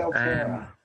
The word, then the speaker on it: Altaira: The pronunciation is Altaira. Is that how you pronounce her name? The main protagonist or the main heroine in the film? – Altaira, I think it is. – I'm pretty sure it's Altaira.